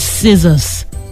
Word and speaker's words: scissors: The double letters SS in SCISSORS are pronounced as A. S. B. SS. C. Z. The spelt word is scissors. Did you hear the z?